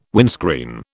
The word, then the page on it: windscreen: British English